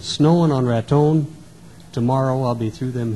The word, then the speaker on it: Raton: It runs through Denver, Colorado Springs, Pueblo, Walsenburg, little town of Trinidad, and it goes up over a pass into Raton, New Mexico. That's the way they pronounce it out there, too, Raton.